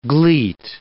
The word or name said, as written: gleet